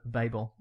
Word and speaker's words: Babel: Okay, so basically this story was influenced by the Tower of Babel. Or Babel? Yeah. How do you say it? – I say Tower of Babel.